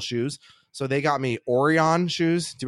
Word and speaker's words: Orion: I just know that O-R-I-O-N is always pronounced Orion. Oh. But dumb people who think that they remember things when they were three pronounce it Orion.